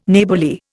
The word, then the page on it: Neighbourly: Indian